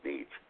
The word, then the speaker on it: Nitschke: Do we have any more good advice from Pete Nitschke? – Pete Nitsch. Yes, we do. – Oh, Nitsch. Is that how you pronounce it? – Yeah.